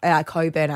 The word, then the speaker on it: Cockburn: Go down the beach down there at Cockburn. – Coburn, actually.